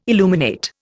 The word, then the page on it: illuminate: Indian